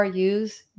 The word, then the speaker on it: ewe: Yous, you. Am I saying it right? Sheep, you. – Sheep. – Female sheep is a you. Is it? – What is the you? – E-W-E, right? – What's that? – I have to look that one up. E-W-E, isn't that a female sheep?